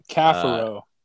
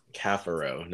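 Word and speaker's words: Caffaro: Oh, I know he just changed the pronunciation because everyone was going crazy about it. – Cafaro. – Cafaro, not Cafaro, Cafaro. But he did tweet out saying, whichever way you say it, it does not offend him.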